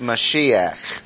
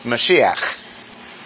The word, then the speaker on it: Mashiach: It's in Hebrew pronounced Mashiach. And if you're a purist, the CH on the end is like that. Mashiach. Mashiach.